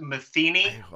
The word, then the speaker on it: Matheny: It's pronounced Matheny, but it's the most common mistake and mispronunciation in the world. You have to think of like Pat Metheny, the famous jazz musician.